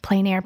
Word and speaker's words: plein air: One of the mediums you thoroughly enjoy is plein air painting. – Yes. – Did I say that right? – Yes, plein air painting.